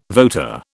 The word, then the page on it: voter: British English